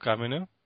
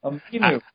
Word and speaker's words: Aminu: Al-Farouq Aminu? – Okay.